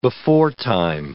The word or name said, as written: beforetime